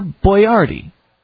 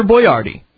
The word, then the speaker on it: Boyardee: Hector knew that his canned pasta bearing his name would be mispronounced by most Americans, so he just spelled it phonetically and ended up with Chef Boyardee.